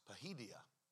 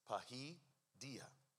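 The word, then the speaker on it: paideia: It is a word that you pronounce pahidia, pahidia.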